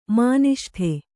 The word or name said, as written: ಮಾನಿಷ್ಠೆ